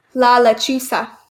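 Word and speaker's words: La Lechuza: La La Chusa. Yes. – La La Chusa. – Sorry. It's spelled with a Z, but it is pronounced with an S. La La Chusa.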